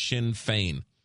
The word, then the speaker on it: Sinn Féin: The correct pronunciation for the political party is Sinn Féin.